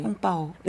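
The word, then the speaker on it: 홍빠오 (红包): 홍빠오.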